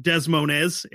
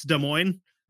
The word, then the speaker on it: Des Moines: The capital of Des Moines, which is not pronounced Des Moines, it's Des Moines.